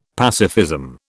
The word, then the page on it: pacifism: British English